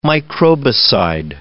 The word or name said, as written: microbicide